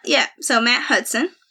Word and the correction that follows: Huston: It's Huston, not Hudson.